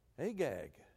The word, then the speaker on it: Agag: Agag. You remember Agag? Anybody remember Agag in the Bible? There is a possibility that this guy is an Agagite because he's a descendant of Agag.